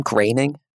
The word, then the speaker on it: Groening: I think Matt Groening, is that how you say his name? Groening? – Yeah.